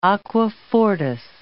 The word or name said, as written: aqua fortis